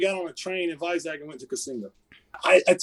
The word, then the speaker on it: Vizag: It's Vizag, actually. – Vizag. – I think they pronounce it Vizag.